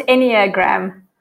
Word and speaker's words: Enneagram: So yes, today is, I'm quite excited about actually, because, well, I should probably ask you, is it Enneagram? Enneagram? – Yeah, it's pronounced Enneagram.